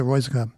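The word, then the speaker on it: Röyksopp: And they're called, I'm going to say it quick, so you can't really tell if I mispronounced it or not, Royskop. R-O-Y-K-S-O-P-P is how they pronounce that, or that's how they spell it. I pronounce it Royskop.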